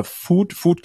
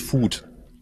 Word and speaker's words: Voet: Oder Food, glaube ich, spricht man es aus. Das muss ich auch erst nochmal lernen. Ich glaube, es ist Food.